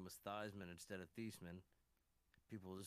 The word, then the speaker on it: Theismann: It's actually pronounced Theismann. But, and you know, they wanted his, he was going for the Heisman.